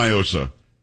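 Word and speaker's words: NIOSA: Do not, because it's definitely Nyosa. It is not a Spanish word. What does Nyosa mean in Spanish? Nothing. – I don't know. – Well, that's the whole argument. They try to pronounce it like it's Spanish. It's not.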